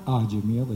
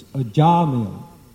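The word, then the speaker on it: Ajamila: It's not Ajamil, it's Ajamil. The second syllable is long.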